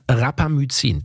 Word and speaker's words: Rapamycin: Ich weiß nicht, wie man es ausspricht. – Ich glaube, es heißt Rapamycin. Das ist der Begriff, den du suchst. – Ja, so kann man es auch aussprechen.